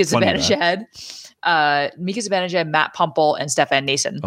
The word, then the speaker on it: Nason: It is Nason, not Nosen. for anybody. I've heard it mispronounced.